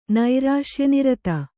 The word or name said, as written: ನೈರಾಶ್ಯನಿರತ